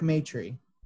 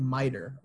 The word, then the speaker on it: Maitre: But it's spelled M-A-I-T-R-E. So it looks like it's spelled like, said like miter or mater. – Yeah, that's how you say it. – From Cars 2. – Yeah, right. – But it's Matry. All right.